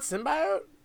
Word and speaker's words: symbiote: It's not symbiote? Symbiotic relationship. – Yeah, that's what always confuses me. – It's pronounced symbiote. I know, it's weird.